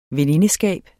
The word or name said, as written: venindeskab